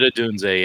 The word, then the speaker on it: Odunze: At 107, it's was it Rome Odunze? Is that how you say his name? – He wanted Odunze, yeah.